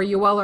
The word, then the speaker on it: Joel: And Jake Evans and Joel Armia are both, or Yoel Armia, to pronounce it correctly, are both that kind of guy.